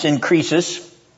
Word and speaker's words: synkrisis: But I want to begin with that word synchresis, which is a Greek word that means comparison. It means comparison. It's pronounced synkrisis.